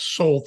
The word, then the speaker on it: Sol: Well, how'd you come up with the name, the Shining Soul Company, which is spelled SOL? – So soul is for sun, shining soul.